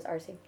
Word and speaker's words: Arce: So my last name is Arce. – Yeah. Mm-hmm. Like the cola. – I guess. It's easier to say. I guess it's A-R-C-E, so my dad's from Ecuador, and it's pronounced Arce.